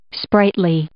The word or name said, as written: sprightly